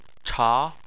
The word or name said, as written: cha2